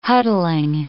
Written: huddling

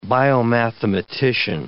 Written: biomathematician

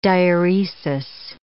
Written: diuresis

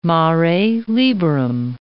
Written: mare liberum

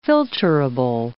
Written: filtrable

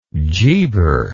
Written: geber

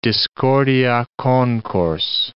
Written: discordia concors

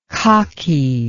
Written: kaki

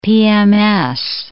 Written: PMS

